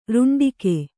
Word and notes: ರುಂಡಿಕೆ: ruṇḍike

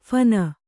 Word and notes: ಫೋನು: phōnu

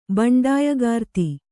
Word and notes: ಬಂಡಾಯಗಾರ್ತಿ: baṇḍāyagārti

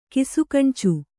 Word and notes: ಕಿಸುಗಣ್ಚು: Kannada

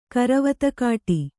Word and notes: ಕರವತ್ತುಕಾಟಿ: karavattukāṭi

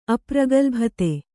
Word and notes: ಅಪ್ರಗಲ್ಭತೆ: Kannada

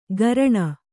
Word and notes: ಗರಣ: Kannada

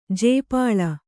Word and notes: ಜಾಪಾಳ: jāpāḷa

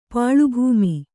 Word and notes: ಪಾಳುಭೂಮಿ: kannada

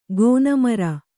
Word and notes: ಗೋನಮರ: gōna mara

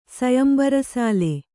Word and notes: ಸೊಯಂಬರಸಾಲೆ: sombara sāle

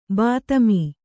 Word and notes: ಬಾತ್ಮಿ: bātmi

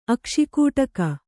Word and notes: ಅಕ್ಷಿಕೂಟಕ: Kannada

